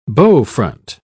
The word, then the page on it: bowfront: British English